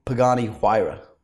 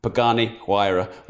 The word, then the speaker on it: Pagani Huayra: Pagani Huayra. – Pagani Huayra.